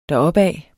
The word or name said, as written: deropad